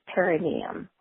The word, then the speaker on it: perineum: The only thing is the pronunciation is perineum.